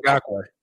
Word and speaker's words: Ngakoue: Yannick Ngakoue.